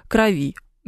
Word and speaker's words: крови: Правильно, в крови или крови, спрашивает Рамси Болтон. В крови. Но анализ кого-чего крови, да, смещаемое ударение, очень сложная история, приходится все время проверять.